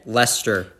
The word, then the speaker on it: Leicester: Red Leicester. – That means there's a silent ICE in that word, and I'm really upset about it.